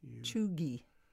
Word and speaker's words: cheugy: C-H-E-U-G-Y. Pronounced Chugy.